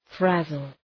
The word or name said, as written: frazzle